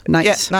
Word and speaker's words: nice: Og udtalt med stød. Nice.